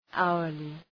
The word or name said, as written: hourly